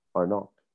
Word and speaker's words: arnaque: Arnok.